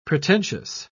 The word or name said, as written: pretentious